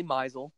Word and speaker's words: Meisel: even though my mom gets pissed off because it is technically Meisel, and it's led to this whole argument. People don't know now. Now I don't even know how to pronounce my own last name.